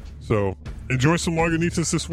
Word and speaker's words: Lagunitas: Something else I noticed on the can is they actually tell you how to pronounce it on the can. It actually says Lagunitas.